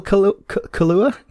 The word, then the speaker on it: Kaluuya: Is it Daniel Kaluuya? Kaluuya's a drink, Kaluuya, isn't it? Kaluuya. – Yeah, Kaluuya. Well, I think it's pronounced like the drink, yeah, but it's Kaluuya.